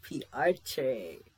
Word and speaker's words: PRTE': And again, we have with us PRTE.